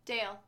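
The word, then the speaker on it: Dayle: I don't know how to say the name. It's D-A-Y-L-E. – Dale.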